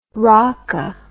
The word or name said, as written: raca